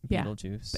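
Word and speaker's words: Betelgeuse: It's the everything you see in there is G-E-U-S-E. – It's just they pronounce it Beetlejuice. – Yeah. And the movie title is with J-U-I-C-E.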